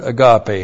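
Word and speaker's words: agape (ἀγάπη): And then we have John's other favorite word, agape. Hey, agape. A-G-A-P, long E. Agape, this is virtue love.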